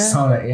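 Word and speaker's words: Sonne: I like Sonnet, S-O-N-N-E. I don't want to butcher it. Sonnet? – Sonnet, yeah.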